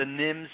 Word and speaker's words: NIMS: So let's talk about what is an emergency operations plan under the NIMS. Am I saying that right? – Yeah, that's right.